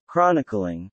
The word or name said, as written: chronicling